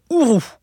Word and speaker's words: Uru: Il est fait en Uru, ou Uru, ça s'écrit U-R-U.